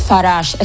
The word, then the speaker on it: Farage: Farage, ¿cómo es? – Farage. Es que suena tan francés que es curioso que el hombre sea tan antieuropeísta.